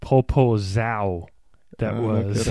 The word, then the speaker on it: Zao: I've got a lot of different opinions on how to pronounce it on the internet, but I get Zao. Z-A-O. Z-A-O. – Zao. – It's just Zao.